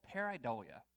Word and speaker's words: pareidolia: In psychology, there's a condition, and I believe I'm pronouncing it correctly. It's called pareidolia.